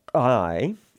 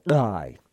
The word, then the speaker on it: Ai: Now Ai, and that's as close as I can get to the Hebrew. The Greek is interesting because it's actually G-A-I in the way that it's spelled, which represents that guttural first sound, Ai.